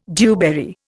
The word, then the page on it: dewberry: Indian